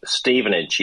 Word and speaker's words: Stevenage: And the clip that we played at the beginning, that was, how do you pronounce this team? It's a fourth division team. – Yep. – Stevenage? – Stevenage, yes. Stevenage FC.